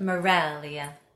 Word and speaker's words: Meraglia: It's because I married an Italian and nobody can say or spell Maraglia with a G in the middle. – Wait, did I say it wrong? I always said it with a G. – I know. It's Maraglia? – Maraglia. – With a silent G?